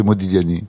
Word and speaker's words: Modigliani: Vous dites quand vous voulez, mais on ne doit pas prononcer pas le G. – Non, c'est Modigliani.